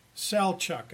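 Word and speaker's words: Selçuk: And it no longer exists as a city that's lived in, but they've done a lot of archaeological work, and it's near a city called Selcuk, Selchuk, as I understand that's pronounced.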